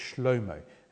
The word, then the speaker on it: Shlomo: And when you pronounce it in Hebrew, it's Shlomo, and it derives from Shalom, peace.